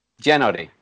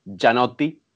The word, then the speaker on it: Giannotti: I actually mispronounced it because it's an Italian surname. It's pronounced Giannotti without the I.